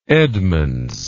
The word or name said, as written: edmunds